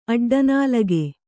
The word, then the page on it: ಅಡ್ಡನಾಲಗೆ: aḍḍanālage